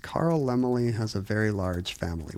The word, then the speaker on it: Laemmle: It's spelled L-A-E-M-M-L-E. – I've always assumed it's Lemley, because in old Hollywood, they would say, Carl Lemley has a very large family.